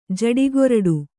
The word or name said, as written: ಜಟ್ಟಿಗರುಡಿ